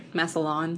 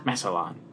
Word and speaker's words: Massillon: That's what the GPS calls it. – Yep. – Massillon. – That's why I always say it like that. – Ah. – Massillon.